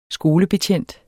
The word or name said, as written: skolebetjent